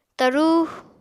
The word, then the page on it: tarú: Apatani